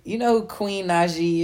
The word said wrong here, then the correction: Naija: is? – Queen Naija. – Is that how you say her name? – Yeah. That's how you say that girl name.